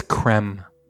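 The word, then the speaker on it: crème: Initially, the base spirit in this drink was creme de menthe, which I've not tried. – I think it's creme. – Creme, okay.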